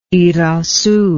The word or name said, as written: irazu